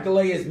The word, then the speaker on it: Galea: Galea's Maltese. – Is it? – Yeah. – Oh. He is Galea. Galea is the correct pronunciation. – Anthony is not Galea, he's Galea.